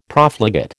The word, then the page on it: Profligate: American English